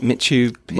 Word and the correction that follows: Machu Picchu: Machu, Machu Picchu. Picchu. Machu Picchu.